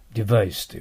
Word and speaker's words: device: デバイスと言わないと。下唇を絶対噛んでください、V は。これとても大事ですよ。噛まないと B の発音になりますよ。ね。デバイスと言ってください。